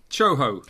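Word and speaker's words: tjoho: Choho.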